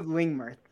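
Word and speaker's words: Lingmerth: And then my second pick, I don't even know if I can pronounce this guy's name, but it's Lingmurth. Did I say that correctly? – Yeah, David Lingmurth.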